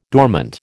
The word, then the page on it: dormant: American English